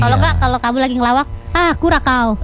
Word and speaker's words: Curaçao: Momen haru juga menyelimuti ternyata ya ribuan warga Curaçao bacanya ya. Jadi C pertama itu jadi K, yang kedua jadi C. – Curaçao. Aduh. – Curaçao Mania.